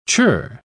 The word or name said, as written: chirr